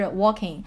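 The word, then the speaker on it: Joaquin: 因为他的名字就是 Walking，是一个西班牙语，J 是不发音的，所以是 Walking。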